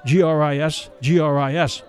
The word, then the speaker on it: gris-gris: spelled G-R-I-S-G-R-I-S, but pronounced gris-gris, for a price.